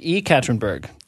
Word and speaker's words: Yekaterinburg: E Katrinburg.